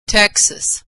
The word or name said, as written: Texas